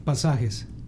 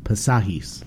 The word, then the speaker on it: Pasajes: I know the English way to pronounce it, Passages, but is it, how do you say it in Spanish? – Pasajes. – Pasajes.